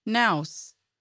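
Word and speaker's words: Knauss: The future First Lady worked in Milan and Paris under the name Melania Naus, the German pronunciation of her name.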